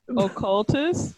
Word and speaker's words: occultist: The inebriated imp known as Kalua was formerly the pet of an oculist. That's not how you say it. – Occultist.